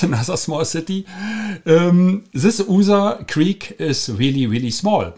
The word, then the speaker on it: Usa: The writing is USA, yeah? But the name is USA in German pronunciation, USA.